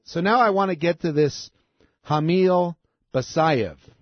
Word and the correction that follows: Shamil: Oh, I mispronounced his name. There's an S at the beginning of it. It's not Ham, it's Sham. Sham, S-H-A-M. Shamil Basayev.